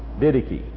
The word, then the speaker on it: Didache: D-I-D-A-C-H-E. Didache.